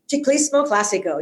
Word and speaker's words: Ciclismo Classico: Or if you can't wait, you can go straight to Ciclismo Classico. Did I say that right? – Ciclismo Classico. Yes. See.